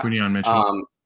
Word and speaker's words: Quinnen Mitchell: Like realistically, the only one that I really saw was their first pick, Quinnen Mitchell. That's how you say his name, right? Yeah. – Quinnen Mitchell.